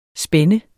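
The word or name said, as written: spænde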